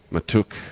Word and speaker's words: Mahtook: M-A-H-T-O-O-K. – M-A-H-T-O-O-K. Matuk.